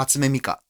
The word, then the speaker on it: Hatsune Miku: Hatsune Miku. – Hatsune Miku.